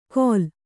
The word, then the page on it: ಘೋಲ: kannada